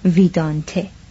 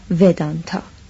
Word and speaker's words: ویدانته: مثلا نوشتم ویدانته وی ای دی ای ان تی ای نه ودانتا که این نه تلفظ سانسکریت است و نه به آن نزدیک و نه فارسیده است